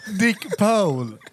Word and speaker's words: Dick Pole: Hur uttalar du på? Är det inte Dick Paul? – Eller är det Paul? – Paul. – Dick Paul. – Dick Paul. Okej.